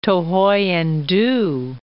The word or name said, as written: Thohoyandou